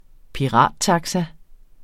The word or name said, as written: pirattaxa